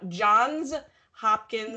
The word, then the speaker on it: Johns Hopkins: And I did the unbelievable thing of actually almost saying Johns Hopkins Hospital. Can you imagine? Like it's so, no, Johns Hopkins. Yeah. I'm like an alumni. I can't even say it right.